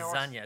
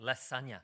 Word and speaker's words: lasagna: Why do you say it with an S sound? It's a Z sound. That's how they say it. It's lasagna. It's not lasagna.